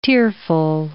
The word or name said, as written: tearful